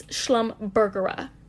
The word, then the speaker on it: Schlumbergera: I said it wrong. It's Schlumbergera. Schlumbergera. Not Schlumberger. I guess that's it's an oil company. Schlumbergera. That's what it is.